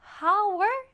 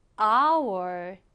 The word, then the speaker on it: hour: 얘는 H로 시작하니까 hour 아니고요. 맨 앞에 있는 이 H가 소리 나지 않는 무음입니다. 그래서 마치 H가 없는 것처럼 hour 이렇게 발음을 해요.